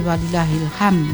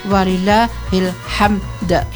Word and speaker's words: walillahilhamd: takbiran yang sering salah itu pengucapannya yang terakhir walillahilham, yaitu jadi itu bahaya. Ham itu artinya adalah sumpek ya, ham artinya sumpek, jadi harus walillahilhamd ya.